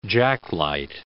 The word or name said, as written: jacklight